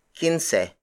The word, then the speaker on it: kinse: Up next is quince. Quince. Quince. Quince is spelled as K-I-N-S-E.